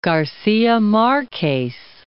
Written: Garcia Marquez

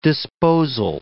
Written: disposal

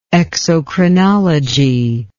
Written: exocrinology

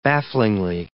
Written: bafflingly

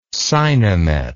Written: Sinemet

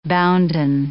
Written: bounden

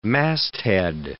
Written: masthead

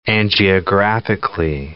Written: angiographically

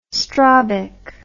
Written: strobic

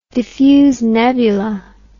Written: diffuse nebula

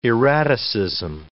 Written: erraticism

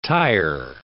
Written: tyer

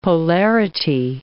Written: polarity

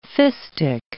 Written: fistic

